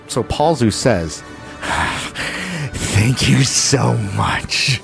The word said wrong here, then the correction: Polsbo: It's pronounced Polsbo, first of all.